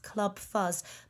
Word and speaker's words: Clwb Fuzz: And if you want to find them on social media, they are at Club Fuzz, but it's spelt C-L-W-B-F-U-Z-Z.